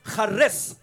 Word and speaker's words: charis: So grace is a Greek word. It's pronounced charis.